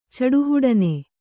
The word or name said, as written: ಚಡುಹುಡನೆ